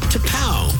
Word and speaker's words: T'Pau: T-apostrophe-P-A-U, pronounced T-P-A-U.